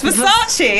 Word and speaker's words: Versace: Yes, Versace. Yes. Versace. – I always pronounce it as Versailles, and I don't know why.